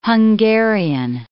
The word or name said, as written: Hungarian